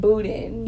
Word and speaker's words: Boudin: Boudin.